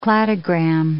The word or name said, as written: cladogram